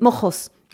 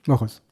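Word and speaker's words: Moxos: Donc, c'est avec un X, mais c'est en espagnol, on peut le prononcer avec comme un G, comme, voilà, comme Jérôme, hein. Mais c'est Mojos. – Mojos.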